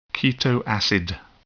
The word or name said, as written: keto acid